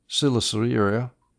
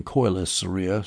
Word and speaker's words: Coelesyria: All of Ammon and Moab were in Silicearia, which I have been erroneously pronouncing Coelesyria. Sorry about that.